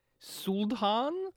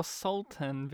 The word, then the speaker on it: Sultan: Sultan or Sultan? Because they pronounce it both ways in this film.